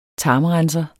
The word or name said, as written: tarmrenser